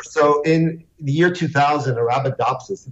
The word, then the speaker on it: Arabidopsis: but the Arabidopsis plant. – Arabidopsis, sure.